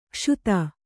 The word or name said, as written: ಕ್ಷುತ